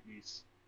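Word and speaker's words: Ys: To figure it out, but it's actually East. – East. – East. That's what it's pronounced. It's pronounced Wise. – East. It's pronounced East.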